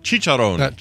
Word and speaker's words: chicharrón: And I did mispronounce chicharrón. – Chicharrón.